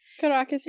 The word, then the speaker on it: Caracas: Caracas, yeah.